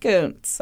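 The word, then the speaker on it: Kuntz: It's spelled like cunts, guys. But it's pronounced Coots, I swear.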